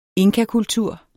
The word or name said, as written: inkakultur